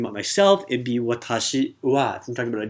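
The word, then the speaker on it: wa (は): And that wa, although it's said like, it's written like wa, the ha in hiragana, it's going to be pronounced wa.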